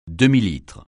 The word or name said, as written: demi-litre